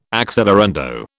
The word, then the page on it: accelerando: British English